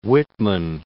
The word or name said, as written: Whitman